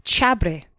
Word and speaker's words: xabre: and it's called chabre. Now this is spelt with an X because remember there's lots of X's in all of their words, so it's spelt X-A-B-R-E.